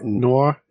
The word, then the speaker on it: noir: So really quick, Black No, how do you pronounce that? Black No, No, No, what? – Noir, Noir. – Thank you.